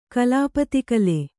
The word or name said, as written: ಕ್ಲುಪ್ತಕಾಲ